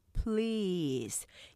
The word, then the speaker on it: please: 우리가 그러니까 보통 우리가 해주세요 했을 때 please 이게 한글로 표현하면 please 하기 때문에 삼음절로 돼요. 그러 실제적은 이름적이죠. 길게 please 이렇게 길게 하나의 음절로 발음하셔야 정확하게 하실 수 있고요.